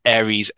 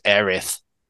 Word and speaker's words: Aerith: And also Ares, Aerith.